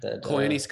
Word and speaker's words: Koyaanisqatsi: It's kind of like Kayana Stasi from am I saying that right? – Koyaanisqatsi? Scotzi? – Yeah, you know that documentary?